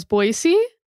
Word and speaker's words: Boise: And from what I found, most locals, I think, pronounce it as Boise, whereas a lot of other people pronounce it as Boise if they're not from the area.